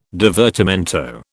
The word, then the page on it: divertimento: British English